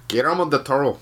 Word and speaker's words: Guillermo del Toro: Guillermo del Toro. That's how you say it.